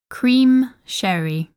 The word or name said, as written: Cream Sherry